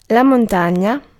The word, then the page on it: mountain: English